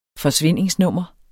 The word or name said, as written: forsvindingsnummer